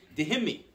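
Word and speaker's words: Dhimmi: And the Jews had a status called, I don't know how to pronounce it exactly, but I believe it's Dehimi. Dehimi is a status essentially where anyone who's non-Muslim gets second-class citizenship, but they're protected.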